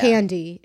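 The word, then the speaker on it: Thandie: spelled Thandy, but it's yeah. Tandy.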